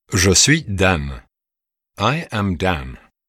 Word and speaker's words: Dan: Dans Dan, N s'entend comme dans le français can.